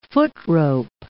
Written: footrope

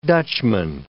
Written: Dutchman

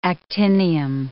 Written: actinium

